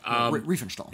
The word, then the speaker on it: Riefenstahl: In particular, Leni Riefenstahl. I think I'm pronouncing that right. I could be wrong. – Riefenstahl. – Is it Riefenstahl? – Yeah.